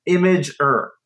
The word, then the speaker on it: Imgur: The pronunciation, according to their website, is Imgur. So Imgur. – Okay. – Oh, that's bullshit. – What? – It comes from your, like you are, your image. So it's Imgur, IMG, the file extension for an image, and UR for your.